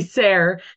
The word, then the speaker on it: Sayre: Tracy Sayre. It rhymes with hair.